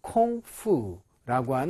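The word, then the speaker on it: 콩푸라고 (孔夫): 그래서 여기에 나와 있는 콩푸라고 하는 요 말이 자, 사실은 우리말로는 공부라고 읽지만 자, 중국어로는 콩푸라고 읽어요, 실제로.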